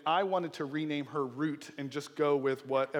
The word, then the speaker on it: Ruth: The two sons marry Orpah and Ruth, and the name Orpah means gazelle, and the name Ruth is pronounced root. And if you know it, it still is pronounced that in different languages today, which means friendship.